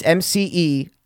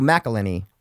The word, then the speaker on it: McElhenney: It's McElhenney. So McElhenney, like Mac.